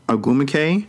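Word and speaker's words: Ogwumike: as well as Diana Taurasi and Nneka Agumike. I don't know if I said her first name wrong, but that's how you definitely say her last name.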